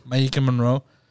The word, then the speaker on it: Maika Monroe: Maika Monroe.